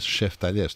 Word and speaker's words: σεφταλιές: Θα έχουμε σούβλα, θα έχουμε σεφταλιές, σκούπες, χαλούμι. – Μ' αρέσει που το σεφταλιές το λες σεφταλιές, το λες και εσύ με την Κυπριακή.